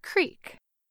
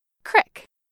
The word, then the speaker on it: creek: Most Americans say this word as creek, with a long E sound, like in the word beet. But in some parts of Appalachia, like western Pennsylvania, West Virginia, and the Ohio Valley, you might hear this word with an I sound, as in crick, like in the word tip.